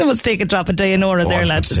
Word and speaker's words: Dynoro: Dynoro or Dynoro, Laura? What would you say? What's your pronunciation? – Oh, good. Dynora.